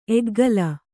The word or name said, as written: ಏಕಕಾಲ